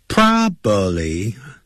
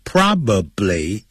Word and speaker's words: probably: probably 这样念的不对的，叫 probably 啊，probably 这样念，p r o b a b l y probably 可能会昏倒，faint 不要念成 faint aiding。